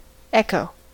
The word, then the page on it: echo: American English